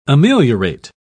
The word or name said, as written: ameliorate